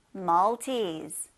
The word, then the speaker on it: Maltese: it's a Maltese. 네, 말티즈를 영어로 Maltese 이렇게 발음합니다. L 발음 살려서요.